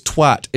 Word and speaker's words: twat: It's twat. It's pronounced twat. For the love of fucking God, on behalf of the rest of the world, stop pronouncing it twat, you fucking imbeciles. It's twat. It rhymes with cat. It rhymes with hat.